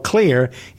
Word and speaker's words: Xlear: It's called Clear. It's spelled X-L-E-A-R.